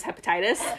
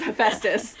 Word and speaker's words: Hephaestus: I used to pronounce his name as Hepatitis, because I didn't realize I was Hephaestus.